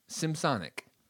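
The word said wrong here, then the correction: Semisonic: It's semi-sonic.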